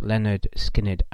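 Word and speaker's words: Lynyrd Skynyrd: That was a Simple Man of Leonard Skinner's, pronounced Leonard Skinner album.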